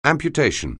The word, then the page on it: amputation: anglais